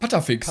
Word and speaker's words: Patafix: wie heißt Pat, Pat, Patafix? Pat, Patta, doch, dann bin ich schon richtig Pata. Okay, habe ich schon richtig gesagt.